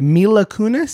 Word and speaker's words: Mila Kunis: Mila Kunis. – There you go. That's her. – Okay. Now, after watching this movie, I don't mean to be mean to Mila Kunis,